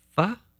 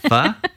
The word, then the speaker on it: pho: Everybody says you make it short, but the Vietnamese fellow that was our tour guide said when you say the word, smile. And that gives the correct intonation for the correct pronunciation of pho.